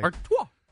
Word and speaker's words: Artois: Man, I'm shocked you could pronounce that correctly. – Artois. – Can you spell it? – A-R-T. O-I-S.